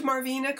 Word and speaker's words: Marvina: Tell me that I pronounced Marvina correctly. – You did.